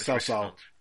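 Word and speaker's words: Cao Cao (曹操): I know that was Cao Cao. – Cao Cao, or the Chancellor, whatever, who is heading to the south.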